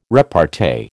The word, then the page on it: repartee: American English